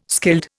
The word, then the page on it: skilled: Indian